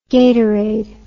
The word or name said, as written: gatorade